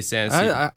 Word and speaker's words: San Ysidro: Ysidro? San Ysidro? Yeah, I'm going to say San Ysidro. – I think you're saying it right.